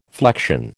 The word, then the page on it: flection: American English